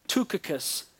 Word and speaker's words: Tychicus: We begin verse 7 with Tychicus. It doesn't look like it should be said that way, but I have been assured by someone who speaks and reads the language that that's how you say it.